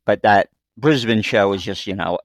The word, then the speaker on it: Brisbane: You know, like a lot of Americans say Brisbane, but we know it's Brisbane. Melbourne is actually Melbourne,